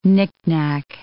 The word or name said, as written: knickknack